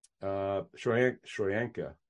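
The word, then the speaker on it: Tryon-Shoyinka: Joe Tryon, Shoyinka. Shoyanka. Yeah, that's how you say it.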